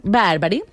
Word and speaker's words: Barbari: La escritora boloñesa Elisa Barbari, ¿cómo se pronuncia? – Barbari,